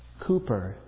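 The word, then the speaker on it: Cowper: Just so you know, there was a letter found of one of his friends that said that the way that his relatives and close friends pronounced his name was Cooper, with two O's. Though it's spelled C-O-W, it's pronounced Cooper, according to his friends and relatives.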